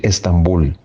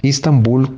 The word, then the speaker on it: Estambul: La tercera pregunta que tenemos hoy es si la ciudad de Turquía se pronuncia Istambul o Estambul. – Según lo determina la Real Academia, tanto en lo que se refiere a la escritura como a la pronunciación, lo correcto es decir Estambul. Ahora bien, Istambul corresponde a la pronunciación turca.